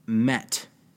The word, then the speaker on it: meet: M-E-E-T was actually originally pronounced met. – That's interesting. So like the past tense. – Yeah. And M-E-A-T was pronounced miet.